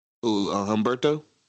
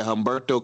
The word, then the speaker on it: Humberto: Humberto? – Whatever you said. – Yeah, Humberto Carrillo.